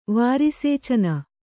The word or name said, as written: ವಾರಿಸೇಚನ